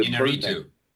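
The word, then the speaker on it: Iñárritu: He directed the Inarritu.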